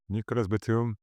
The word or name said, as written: Nicolas Batum